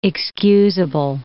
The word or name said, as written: excusable